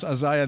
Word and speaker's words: Isaiah: And I know I pronounce Isaiah the English way, not the American way, but you understand, right? You understand my accent? It's Isaiah.